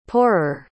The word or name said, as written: pourer